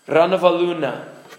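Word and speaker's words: Ranavalona: The O there is pronounced U. Ranavaluna.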